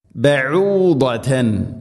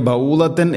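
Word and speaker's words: ബഊദതൻ (بعوضة): മസലം ബഊദതൻ, ഇവിടേക്ക് ബൗദത്തൻ എന്നൊന്നും ഓതി പോവരുത്.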